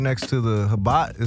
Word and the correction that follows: Chabad: Is that how you say it? – Chabad. – The Chabad. – Yeah, Chabad, next door to it.